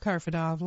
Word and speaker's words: Karfidov: co-founder and senior designer of Karfadov Lab. That's spelled K-A-R-F-I-D-O-V.